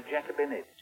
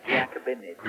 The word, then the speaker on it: Giacobinid: The Giacobinid. – Giacobinid? – Yes, Giacobinid.